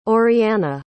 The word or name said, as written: oriana